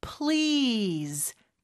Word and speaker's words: Please: PL인데 L 발음이 더 강하게 나야 돼요. 그래서 Lease. Z 발음도 주의하면서 Lease. 이렇게 길게 모음 소리가 나야 되겠죠. 그 앞에 살짝 P를 넣으면 돼요. Please.